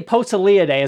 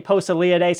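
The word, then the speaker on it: Poeciliidae: Posillidae. I don't know if I'm saying that right. – I usually say Posillidae.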